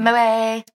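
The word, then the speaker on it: Moët: That's how you pronounce that champagne. – Moe.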